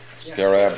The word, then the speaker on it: scarab: Now, the beetle, if you go down to the Ramesses, you'll see the scarab. Is that how you pronounce it? Scarab? – Yeah, scarab.